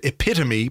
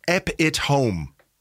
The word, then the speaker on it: epitome: It Let's is from um, Dean who's uh, at Dean Van Wyn, who says, uh, I recently heard the word epitome pronounced ep it home.